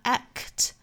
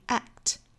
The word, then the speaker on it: act: 我们看 act 这个词里面的这个 k 的发音，因为后面是一个 t 的音，相当于是在单词内部有两个爆破辅音相遇了。那这个时候这个 k 的发音呢是受到一个爆破阻碍，所以不能够很顺畅的爆破出来，不能发 act, 应该是 act, 非常轻微的一个小小的 k 的音，这里面是属于叫做失去爆破。